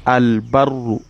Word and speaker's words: al-barru: The next example you see there is al-barru. The lamun comes out clearly.